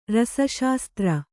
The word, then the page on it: ರಸಶಾಸ್ತ್ರ: rasa śastra